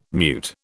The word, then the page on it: mute: British English